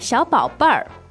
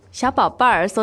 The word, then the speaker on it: 小宝贝儿: 小宝贝儿. – 所以我现在就把小宝贝儿带走了。你们还在等什么? – 小宝贝儿, so they have the Beijing 儿化音. – Oh, yeah, that sounds cute. How do you say it without the R sound? – 小宝贝。